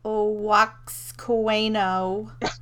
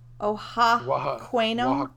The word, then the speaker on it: Oaxaqueño: Then we have tostada de carne, which is braised beef on a corn tostada with mole oaxaqueño and chicharrón. – The X is pronounced like an H. – Oaxaqueño.